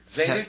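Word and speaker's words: Tzadik: Tadzik? Is that his label? – Yeah, well, how do you say it? Zadik? Or Zadik? – Okay. Yeah.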